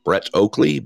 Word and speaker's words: Brett Oakley: Brett Oakley, B-R-E-T-T, last name O-A-K-L-E-Y.